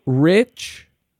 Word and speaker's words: Rich: R-A-L-P-H-I. – Mm-hmm. Last name? – Rich. R-I-C-H.